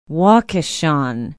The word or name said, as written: waukechon